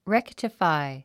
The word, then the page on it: rectify: réktəfài; レクテファイ